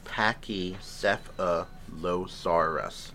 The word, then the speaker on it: Pachycephalosaurus: It's pronounced Pachycephalosaurus. – It's kind of what I just said.